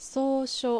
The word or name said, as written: Sousho